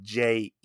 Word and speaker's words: Jei: He's like, hey, my name is Miyamoto Usagi, and he, uh, Jei's like, you can call me Jei, and it's spelled J E I. And a funny thing about this, like, it's pronounced J, and I think Stan Sakai even pronounces it J, but the way it's spelled makes you think it's a little different.